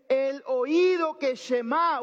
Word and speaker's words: Shemá: Esta palabra se pronuncia Shema y de ahí es donde viene el nombre de la oración. Escucha. Hermanos, Shema es una palabra realmente común en la, en la Biblia.